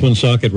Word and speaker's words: Woonsocket: Nationally, the president even pronounced Woonsocket, Rhode Island properly.